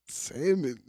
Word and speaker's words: salmon: Salmon, nigga. Ain't that? it's salmon. – What? That sound. – It's not salmon. It's salmon. – I don't know. – The L is silent.